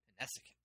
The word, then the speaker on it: Connecticut: Why is there that T in Connecticut that nobody, that C rather, in the middle of Connecticut that nobody pronounces? – Connecticut.